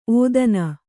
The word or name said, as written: ಔತನ